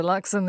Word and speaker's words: Luxon: But it is Luxon with no T. – For the record. – For the record. – It's Christopher Luxon, the new leader of the National Party, with Lisa Owen.